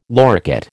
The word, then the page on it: loricate: American English